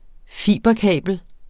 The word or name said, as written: fiberkabel